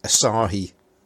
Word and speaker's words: Asahi: Well, I've got to say that's Asahi. That's my settled upon pronunciation of that word now.